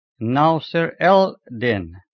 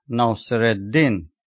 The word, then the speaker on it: Nasir-ed-Din: Entry number 9, Nasir-ed-Din, in Arabic is written Nasir-el-Din, but it is pronounced Nasir-ed-Din.